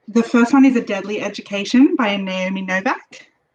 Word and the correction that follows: Novik: Novik. I always mispronounce her name.